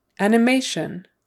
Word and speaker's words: animation: Animation, animation. Accent on the third syllable, sorry, the stress is on the third syllable, animation. And again, the T I O N is pronounced with an S H and then like a U, short U N, shun, shun, shun.